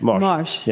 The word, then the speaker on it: morge: And how do you pronounce that word again? – Morge. Yeah, Morge. – You got it. That's it.